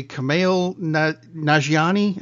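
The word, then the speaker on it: Kumail Nanjiani: It was the star and co-writer of the movie, Kamail Nanjiani. Am I pronouncing that right? Probably not. – Close enough.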